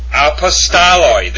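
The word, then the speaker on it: apostoloi (ἀπόστολοι): And actually, it's pronounced apostoloi. There are no omegas in that Greek form at all. There are no long O sounds in apostoloi.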